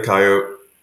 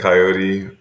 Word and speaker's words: coyote: Usually I say coyote. Every once in a while I get suckered into saying coyote. But as I'm sure you know, both pronunciations are fine.